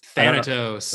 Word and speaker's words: Thanatos: Thanatos. – Is that how you say it? Yep. So I thought that was very interesting.